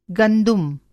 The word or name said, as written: गंदुम